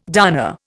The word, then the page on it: darner: Indian